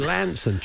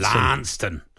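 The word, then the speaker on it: Launceston: I should have said Lanson. Try pronouncing it as Lanson. – Lanston. – Yeah. – Get down Lanston. That's the Cornish way and they'll appreciate it.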